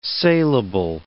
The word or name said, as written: sailable